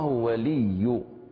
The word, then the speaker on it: ولي: الموضع الثاني وهو النطق بحرف الياء أو الواو المشددة، ومثاله الله ولي الذين آمنوا، الله ولي، إذن هذه الياء مشددة لكل قراء نقرأها بالنبر إنما تكون مشددة الله ولي، ويعني كذلك في قوله تعالى إياك نعبد وإياك نستعين، فهذه هي مشددة في الموضعين فنقرأهما بالنبر إياك.